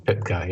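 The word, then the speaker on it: Pytka: Joe Pitka. Is that how you say his name? – Oh yeah. Pitka.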